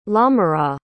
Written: lamorat

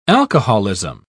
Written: alcoholism

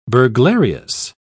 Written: burglarious

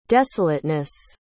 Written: desolateness

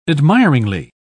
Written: admiringly

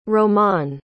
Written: romane

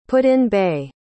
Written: put-in-bay

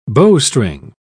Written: bowstring

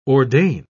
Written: ordain

